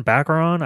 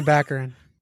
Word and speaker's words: Baccarin: Baccarin? I don't fucking know how to say it. – Oh, you're killing Baccarin. Marina Baccarin. – That's it. Yeah.